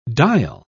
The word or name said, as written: dial